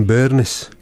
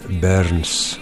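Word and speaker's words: Burns: Το κανονικό επίθετο της οικογένειας ήταν τον Μπέρνες, όπως θα ήταν κανονικά, στην προφορά της βορειοανατολικής Σκωτίας, αλλά ο Ρόμπερτ θα υιοθετούσε αργότερα την παραλλαγή της νότιας Σκωτίας χωρίς το «ι» μεταξύ του «ν» και του «ς», οπότε έγινε Μπέρνς.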